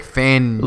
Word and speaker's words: Fangio: Right, Vic Fangio.